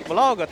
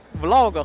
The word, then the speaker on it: vlog: 现在，其实这个 vlog 它是有一段历史的。它本身在我看来就是纪录片的一部分。这个 vlog 很多人发音都会发错，你知道有人会把 vlog 读成 vlog 啊？为什么这个东西不能读作 vlog 呢？是因为这个东西是有一个标准发音的，可以从它的历史聊一聊。